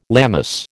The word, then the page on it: Lammas: American English